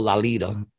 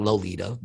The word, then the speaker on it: Lolita: And that's how you say it, people, Lolita. It's like lollipop. I learned that. It's not Lolita, but whatever.